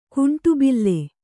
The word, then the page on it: ಕುಂಟುಬಿಲ್ಲೆ: kuṇṭu bille